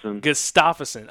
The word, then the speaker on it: Gustafson: Gustafson.